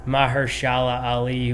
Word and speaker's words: Mahershala Ali: And then Mahershala Ali, who plays...